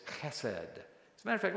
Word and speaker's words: chesed: Can you say that? Chesed. Okay, you kind of almost like you're spitting. It's a chet. Chesed. The accent on the first syllable.